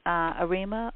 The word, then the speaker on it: Arima: You're also trustee on the board of ASA, Right Nature Center, Arima. – That's ASA Right Nature Center. – Is it in Arima, or how do you pronounce that? Yes, that's in the Arima Valley in Trinidad.